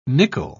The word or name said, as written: nickel